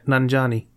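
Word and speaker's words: Nanjiani: Like Camille Nanajani, Nan Nan Nanajani, Nan. I keep pronouncing his name wrong. How do I pronounce his name? Come on. Camille Is that it? Camille. Yeah. Nanjani.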